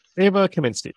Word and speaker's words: Kaminsky: Ava Karminsky? Am I saying her name right? – Ava Kaminsky. – Kaminsky. – Okay. I don't think there's R. – No, there is no R.